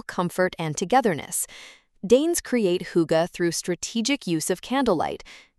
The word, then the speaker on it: hygge: My Danish education began with learning about hygge, pronounced hygge, a concept so central to Danish identity, it's both a verb and adjective.